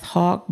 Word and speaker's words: Haugk: And the title of the book is Antagonists in the Church, and it's by Kenneth Hauk, but it's spelled H-A-U-G-K. Antagonist in the Church by Kenneth H-A-U-G-K.